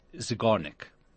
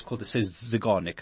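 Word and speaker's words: Zeigarnik: There's an actual gestalt psychology phrase called the, I've got to say it right, Zygarnik. It says Zygarnik effect. And I always remember because it's a cigar.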